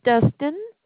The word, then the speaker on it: Dustin: My name is Dustin.